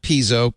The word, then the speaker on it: piezo: Piezoelectric. Okay. Piezoelectrically. – So it is piezo. – Piezoelectric. – Not piezo, piezo.